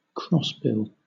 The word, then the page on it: crossbill: British English